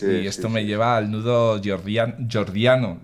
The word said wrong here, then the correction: gordiano: ¿lo he dicho bien? – Gordiano, sí, eso. Gordiano.